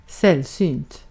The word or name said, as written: scarce